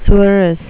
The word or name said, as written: tsuris